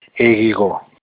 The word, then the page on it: ehigho: Olukumi